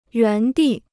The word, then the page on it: 原地: yuán dì